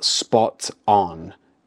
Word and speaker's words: spot on: Firstly, notice the pronunciation. We mix the two words together to sound more natural. So it's not spot on, it's spot on, spot on.